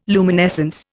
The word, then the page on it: luminescence: Indian